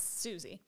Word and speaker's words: Souci: It's spelled S-O-U-C-I, but it's Susie.